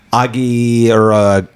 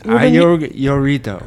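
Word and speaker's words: Agiorgitiko: I don't pronounce G. That's wise. It's Ayurito.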